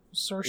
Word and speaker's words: Saoirse: Oh, Saoirse Ronan. – Saoirse. – Yeah. Mm-hmm. – Saoirse. – Like, there's no CH sound.